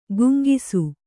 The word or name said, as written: ಗುಂಗಿಸು